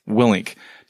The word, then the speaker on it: Willink: I forget what – I think it's Jocko talks about – Wilnick, how do you say his last name? – Willink talks about how the SEALs divisions of labor, essentially you can only effectively lead like five people, mm-hmm.